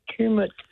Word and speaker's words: Tumut: Just spell it for us. – T-U-M-U-T. Kermit.